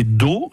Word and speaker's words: Dot: Mais oui, aussi, on peut penser à une variante, parce que il y a une variante où on prononce pas la lettre terminale qui est Do, D-O-T, donc une dot.